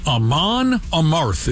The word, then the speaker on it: Amon Amarth: Amon Amarth is how you say it.